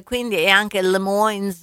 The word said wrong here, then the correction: Des Moines: che... – Des Moines. – Des Moines.